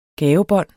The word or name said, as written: gavebånd